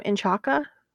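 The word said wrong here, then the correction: Incacha: Oh, what N'Kacha. N'Kacha. We're inverting the syllables.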